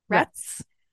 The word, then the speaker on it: Rhetts: We find out that her father works in Washington, and that, I'm not sure how to say this name, Rates, R-H-E-T-T-S? – Rets.